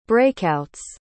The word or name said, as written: breakouts